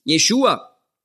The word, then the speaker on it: Jeschua: Dass man einfach hart bleiben und man spricht von Jeschua. Oder? Das ist einfach die hebräische Aussprache für Jesus. Jeschua.